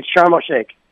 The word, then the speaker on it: Sharm el-Sheikh: I'm not going to pronounce it right. What is that? – It's Sharm el-Sheikh.